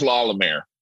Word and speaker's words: La Lumiere: It's Indiana, so it was La Lumiere. There was nothing close to a French pronunciation.